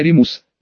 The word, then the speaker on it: Римус: Люпин у меня Римус, потому что на латыни это произносится именно так, через Е.